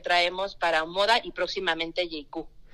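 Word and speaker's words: Jaecoo: Y bueno, para los que se preguntan cómo se escribe JQ, se escribe con JAECWO. No, porque sí, a la hora de buscarla en redes creo que es más fácil como claro, se escribe, porque se pronuncia pues un poquito diferente,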